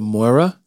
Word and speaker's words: Moira: These lives of Moira? – Yeah. – Am I pronouncing it right? – I believe so.